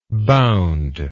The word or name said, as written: boundness